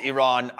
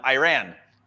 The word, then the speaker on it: Iran: And obviously for anybody that's listening and or watching the at the beginning, Tyler Adams calls Iran, Iran. And it's a common mispronunciation of it.